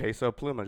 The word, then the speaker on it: Peso Pluma: Peso Pluma, just how you would, yeah. – Cool. – Like Puma with an L in front of the.